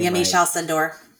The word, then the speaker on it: Yamiche Alcindor: Yamiche Alcindor.